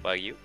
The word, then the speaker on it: Wagyu: Eh, vi, un video que era eh, un restaurante que incluso la carne era de, de ¿Sí se pronuncia Wagyu? Sí. Wagyu.